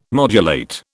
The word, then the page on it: modulate: British English